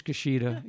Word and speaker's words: Ishkashita: I think it's Ishkashita. Is that right, Barbara? – That's correct. Just how it's spelled.